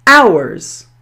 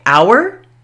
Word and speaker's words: hour: It can be pronounced with the ow sound, as in hour, or it can be pronounced with the short O, the R sound, just like the word A-R-E. So you have two choices here. You can say our or R.